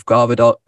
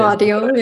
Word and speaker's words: Gvardiol: We actually discovered today from a BBC article that she'd written about Josef Gvardiol. That's how you say it. – Gvardiol, yeah.